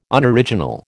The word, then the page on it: Unoriginal: American English